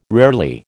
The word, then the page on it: rarely: American English